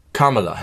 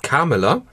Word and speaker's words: Kamala: Aussprache einmal, ich habe es inzwischen gelernt, wir haben es häufig falsch gemacht, du gerade eben auch. Kamala Harris. – Kamala? – Ja.